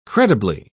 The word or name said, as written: credibly